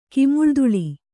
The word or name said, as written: ಕಿಮುಳ್ದುಳಿ